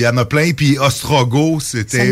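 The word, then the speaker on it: Ostrogoth: C'est des Ostrogos. Donc, on prononce pas le T.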